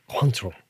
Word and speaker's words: Cointreau: Cointreau. Am I saying that right? – You are saying it right.